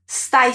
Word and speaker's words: stai: Aqui eu chamo a tua atenção para a pronúncia da letra S no começo da palavra stai. A pronúncia é bem sutil, porque esse S, ele é mudo, na verdade. Então, nada de colocar um som de vogal antes dele, hein?